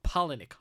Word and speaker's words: Palahniuk: I heard his name pronounced in the mm. Wisecrack video, and now I'm like, what? Uh, Okay. Chuck Palahniuk. Holy crap.